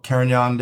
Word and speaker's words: Carignan: Of October, International Carignan Day.